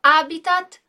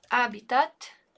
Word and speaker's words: habitat: Bene, então questo è il suo habitat naturale. Novamente aqui uma palavra de origem estrangeira que a gente não vai pronunciar o H, d'accordo?